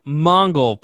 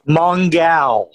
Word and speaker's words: Mongal: Mongol, played by Mei Ling Nguyen. I don't know. I don't know how to say her name. – Sure. It's not actually Mongol. It's like the female Mongol. – Mongal.